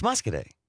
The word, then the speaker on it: Muscoda: What was the town that we thought was Muscaday? – It's Muscaday. – Muscaday. What did we? – It's spelled Muscoda.